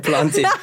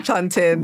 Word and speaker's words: plantain: Is it plantain or is it planted? – It's definitely plantain.